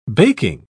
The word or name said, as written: baking